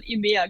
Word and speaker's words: EMEA: Also EMEA würde ich wahrscheinlich selbst nicht so aussprechen. – Ah, okay. in England würden das schon aussprechen, aber es das heißt wahrscheinlich hier in Deutschland EMEA, genau.